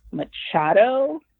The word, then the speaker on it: Machado: The other book I would recommend is Her Body and Other Parties by Carmen Maria Machado. – Mm-hmm. – I believe that's how you pronounce her last name. – Yep.